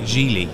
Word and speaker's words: Geely: So there's brands from China like Geely.